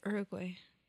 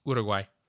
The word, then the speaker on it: Uruguay: I always said Uruguay. – Yes, and a lot of people say Uruguay. No, it's none of those. It's Uruguay.